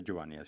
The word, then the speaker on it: Giovanni: Se pronuncia Giovanni, ¿sí? ¿No? ¿Lo he dicho bien? Sí.